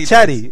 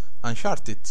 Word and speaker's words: Uncharted: Anchari.